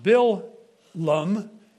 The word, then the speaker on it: Balaam: The most famous curse monger in history is Balaam, Numbers 22, pronounced Bil-lum in Hebrew.